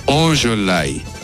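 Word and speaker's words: Ollolai: Es Ololai. Con la L normalita, viste, no como la nuestra.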